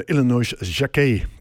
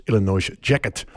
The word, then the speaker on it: Illinois Jacquet: Tenminste, zo spreek ik het uit. De Amerikanen zullen het waarschijnlijk uitspreken als Illinois Jacquet. Maar goed, gezien zijn achtergrond hou ik het maar gewoon bij Jean Illinois Jacquet.